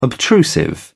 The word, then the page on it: obtrusive: British English